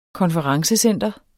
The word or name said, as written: konferencecenter